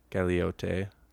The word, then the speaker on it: Galieote: Yeah, and the artist's name is Danny Galote. Galeote. – That sounds pretty good. – I might have butchered that. That sounds decent.